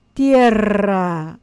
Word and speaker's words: tierra: That's for you, Pepe. That's how you say it, tierra.